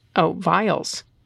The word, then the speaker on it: viols: I'm a musician and a teacher in the Chicago area, double bassist and early music specialist performing music for Violas da Gamba, or Viols, pronounced, Vials. Okay, Vials.